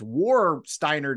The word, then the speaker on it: Warsteiner: So I got, how do you say it's War Steiner to me, but how do you say Warsteiner? – There it is.